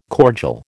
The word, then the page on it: cordial: American English